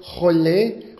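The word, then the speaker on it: Chol (חול): Der Sand ist Chol, C-H-O-L. Chol, ja, notieren Sie.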